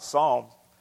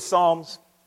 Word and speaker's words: Psalms: we were reading a little bit about Psalms. And I said, do you know the book of Psalms? And she looked at me and she said, yes, the P is silent.